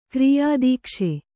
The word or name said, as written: ಕಾರ್ಯದಕ್ಷ